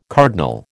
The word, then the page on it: cardinal: American English